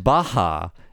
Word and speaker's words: Bach: And the lovely Nina Matsumoto says, a very short comment from her, but she says, Bach is pronounced Baha in Japanese.